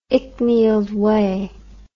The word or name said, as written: icknield way